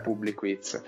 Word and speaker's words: Publiquiz: Post come Squeeze Day o Publiquiz, non so se lo sto pronunciando giusto. Sì, sì. – Come eh, viene sempre da pronunciarlo sbagliato, in realtà è Publiquiz.